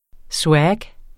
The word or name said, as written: SWAG